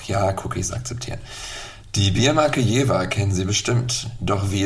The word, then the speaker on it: Jever: Die korrekte Aussprache lautet Jever, also mit einem scharfen F und nicht nicht mit einem weichen V.